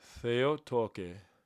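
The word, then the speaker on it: Theotoke (Θεοτόκε): Theotoke.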